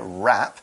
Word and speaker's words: wrap: So this verb wrap is got a W, it's a silent W, but it's there, wrap.